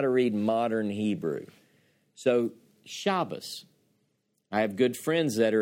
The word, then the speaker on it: Shabbos: And he says, when the Tav appears without a dagesh, it's pronounced one way. When it appears with the dagesh, the dot in the center, it's pronounced another way. And he said, so we pronounce Shabbos because of this rule.